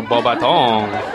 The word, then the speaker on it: Beauxbatons: Well, Beauxbatons.